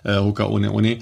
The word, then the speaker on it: Hoka One One: Es wird halt hier Hoka One One ausgesprochen, weil die hawaiianische, das ist ja ein hawaiianisches Sprichwort, Hoka Ohne Ohne.